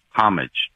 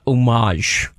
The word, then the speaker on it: homage: First, I want to say the word is homage, okay? – Okay, thank you. It's homage or homage with a less emphasis on the H. It is certainly not homage. That it is not.